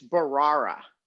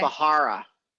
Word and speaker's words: Bharara: Yeah, but before he shows up, though, learn to pronounce his name. It's Bahara, not Bahara. I think you said Bahara.